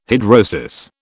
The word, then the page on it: hidrosis: British English